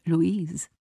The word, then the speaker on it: Louise: He asked her name and where exactly she lived, and pronounced her first name Louise, so cutely the French way, Louise. And